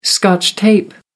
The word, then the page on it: scotch tape: American English